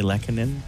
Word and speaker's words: Lehkonen: What'd you say his name was? – Turi Lehkonen.